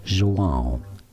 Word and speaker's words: João: João.